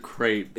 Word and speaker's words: crepe: Tape rhymes with crepe.